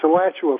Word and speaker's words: Alachua: Alachua, Florida. Do I am I pronouncing it right? – Yes, sir. It's Alachua, Florida, just north of Gainesville.